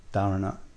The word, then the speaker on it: dharana: I thought we'd start today, this afternoon, with some practices, techniques called dharanas, D-H-A-R-A-N-A, dharana, actually dharana, because it's aspirated in the technical Sanskrit pronunciation.